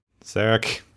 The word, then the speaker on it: Sarek: I can never pronounce Sarek. That right. Sarek? Yeah.